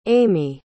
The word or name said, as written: aimi